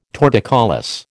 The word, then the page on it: torticollis: American English